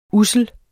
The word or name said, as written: ussel